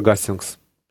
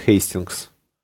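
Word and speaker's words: Hastings: Тем не менее, англосаксы заняли холм недалеко от городка Гастингс, или, как англичане произносят, Хейстингс, где заняли оборону и достаточно долго держались.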